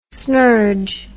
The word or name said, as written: snurge